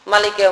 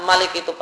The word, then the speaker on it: Maliki: Oleh karena itu maka misalnya untuk surat Al-Fatihah, Maliki Yaumiddin, cara menulisnya ya, meskipun kita baca Malik itu panjang, Maliki Yaumiddin, meskipun kita cara bacanya panjang, namun tulisannya cuma mim, langsung disambung lam, langsung disambung kaf.